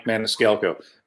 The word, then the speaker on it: Maniscalco: It's at Mike Maniscalco, the name that's in the lower half right there. I know it sounds weird to spell, but it's exactly how you say it. So man is Calco.